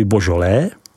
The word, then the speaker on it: Beaujolais: a část Beaujolais, vyslovuj Božolé.